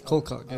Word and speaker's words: Colcock: How do you spell that? – C O L and cock. – No. Colcock. Yeah.